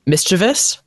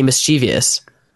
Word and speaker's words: mischievous: When it's like, oh, I hate when people say mischievous. – Right. – But I'm like, is that not how you say it? I'm like, wait, there's no I there. Why am I saying mischievous?